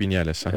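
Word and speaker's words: Viñales: Çünkü mesela onun altındaki Vinales mi? Öyle mi telaffuz ediliyor? – Evet, Maverick Vinales.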